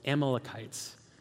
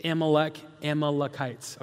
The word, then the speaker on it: Amalekites: there was a nation called the I always say this wrong. It's Amalekites. It's Amalekites, okay?